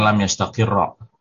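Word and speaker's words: lam yastaqirra (لم يستقر): Kalau fi'il mudhari akhirnya tasydid ada tasydidnya gitu ya. Ketika bertemu dengan huruf jazm dia tetap fathah Bu. Jadi bacanya lam yastaqirra al imanu fi qulubi.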